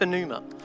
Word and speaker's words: pneuma: It's got a silent P at the start, pneuma.